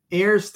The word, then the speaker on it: heirs: "and as being heirs together," I always have a problem with that word, I always say pronounce the H, "as being heirs together of the grace of life, that your prayers may not be hindered."